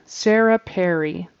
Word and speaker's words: Sarah Perry: Sarah Perry. The first syllables of those two words should rhyme. Sarah Perry is how you pronounce her name.